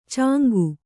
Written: ಚಂಗ

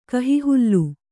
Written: ಕಹಿಹಾಲೆ

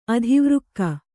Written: ಅಧಿವೃಕ್ಕ

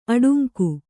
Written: ಅಡಂಕೆ

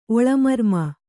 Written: ಒಳಮರ್ಮ

